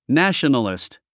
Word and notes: nationalist: nǽʃənəlist; ナショナリスト

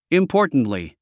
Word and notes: importantly: インポータントリィ

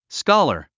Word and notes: scholar: skάlə r; スカラァ

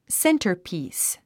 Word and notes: centerpiece: séntərpìːs; センタァピース

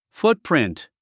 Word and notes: footprint: fútprìnt; フトプリント

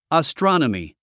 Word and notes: astronomy: əstrɑ’nəmi; アストロノミィ

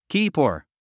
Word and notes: keeper: kíːpə r; キーパー

